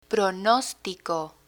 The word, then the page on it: pronostico: Spanish